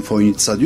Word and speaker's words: Fojnica: Ama çözemediğim şey, telaffuz olarak ben Fojnica diyorum, öyle duydum. Fakat gelen misafirlerin hepsi o C'yi S olduğunu bilmiyor, TS olduğunu bilmiyor ve Fojnica'ya işte gelmekten şeref duydum gibi şeyler zikrediyor.